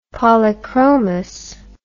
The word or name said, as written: polychromous